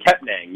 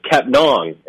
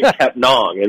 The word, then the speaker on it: Kepnang: Yeah, so originally when he came in, everybody, it was Frank Kepnang, you know, and nobody had really corrected us. And I think, to be honest, that's on people like me for not asking Frank. But the proper pronunciation, we know now, is Frank Kepnong. It rhymes with song, Frank Kepnong.